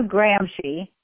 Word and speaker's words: Gramsci: His last name is spelled G-R-A-M-S-C-I, but it's pronounced Gramsci of Italy.